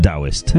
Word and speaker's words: Taoist: I never knew that there Taoist was pronounced Taoist.